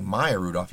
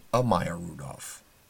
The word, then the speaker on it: Maya Rudolph: And the terrible thing he did was he mispronounced her name. Instead of just saying Maya Rudolph, he was saying a Maya Rudolph.